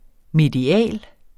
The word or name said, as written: medial